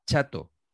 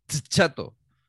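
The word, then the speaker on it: Tchato: Me gusta mucho el naming y lo adelanto ya aquí, que es Bill Chato. Pero escrito con T a la Mbappé, sin pronunciar. Chato. Chato. ¿Os gusta?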